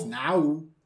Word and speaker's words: Nao: Is it now? – It's now. What did I say? Neo. Now you said neo. Now.